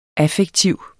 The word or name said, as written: affektiv